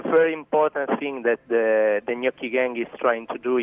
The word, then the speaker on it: gnocchi: I was saying nochi. I sounded like a real American, didn't I? Nochi gang. And it's gnocchi. Is that right? Gnocchi. – It's gnocchi. Yeah, that's it.